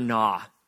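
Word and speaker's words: Na: When I was in college, I had a professor named Dr. Nah, which is spelled N-A,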